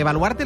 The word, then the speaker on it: Baluarte: Volvemos mañana a partir de las 6, hoy por hoy, especial desde Pamplona, desde Baluarte, ¿lo digo bien? – Correcto. – Desde Baluarte mañana.